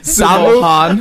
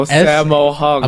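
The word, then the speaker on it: Sammo Hung: Is it Sumo? – Shamo. Sumo Han. – Shamu. – Shamu Han. – No, Sumo Hung. – I'm going to spell it Sammo out for Hung. Him. Okay. So S-A-M-M-O. – Sumo Hung. – Space. H-U-N-G.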